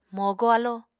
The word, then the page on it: mogo alo: Apatani